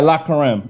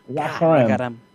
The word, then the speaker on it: La Carême: But the La Carême, is that La Carême? Did I say that right? – Yeah, La Carême. It's French. La Carême.